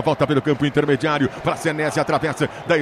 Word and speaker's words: Senesi: Yuri Queiroga, você que é o nosso Yuri pede, a gente vai de Marco Senesi ou Senesi, a pronúncia correta? – Senesi.